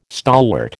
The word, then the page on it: stalwart: American English